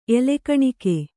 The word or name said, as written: ಎಲೆಕಣಿಕೆ